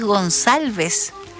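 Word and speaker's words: Gonçalves: No es ocupada en Chile, básicamente, pero esto hace que al tener esa cedilla, aquí un autor nos decía, la C se pronuncia como una S suave. Claro, pues, entonces al señor, además de escribirle mal el apellido, le pronunciaban cualquier cosa también, porque él dice, no, si es Gonsalves. Pero bueno, entonces resulta que el caballero a los 77 años dijo, no, ya, no más.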